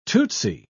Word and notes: tootsy: British English

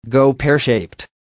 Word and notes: go pear shaped: American English

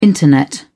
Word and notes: Internet: British English

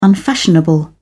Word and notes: unfashionable: British English

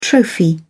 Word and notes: trophy: British English

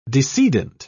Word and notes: decedent: British English

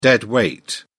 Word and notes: dead weight: British English

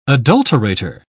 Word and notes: adulterator: British English

